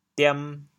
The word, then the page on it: 点: diam2; 潮阳; 文